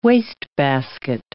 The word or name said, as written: wastebasket